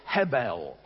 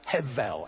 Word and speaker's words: Hevel: The second one is Hebel. Well, actually, it's pronounced Hevel, H-E-V-E-L, Hevel.